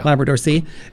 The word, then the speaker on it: Labrador Sea: We're going to play we mentioned the video for Labrador Sea. Did I say that right? – Yes. – Yes. – Yeah. – Labrador Sea.